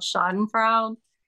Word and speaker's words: Schadenfreude: There's a German word, and I think it's called Schadenfreude, and it means to.